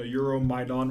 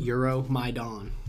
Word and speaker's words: Euromaidan: The Euromaidan Revolution. – Euromaidan. – Yeah, Euromaidan. Euromaidan. – Google it, folks. – Yeah, it's spelled E-U-R-O, Euromaidan, M-A-I-D-A-N, Maidan. Euromaidan. Also known as the Revolution of Dignity,